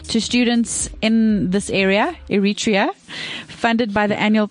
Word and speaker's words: Eritrea: Eritrea? – Yes.